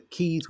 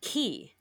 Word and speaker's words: quay: But the keys we're talking about today do not, even though it's spelt the same. – I'm wondering, though, Q-U-A-Y is pronounced key.